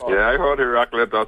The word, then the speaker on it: Heraclitus: Heraclitus.